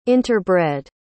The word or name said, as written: interbred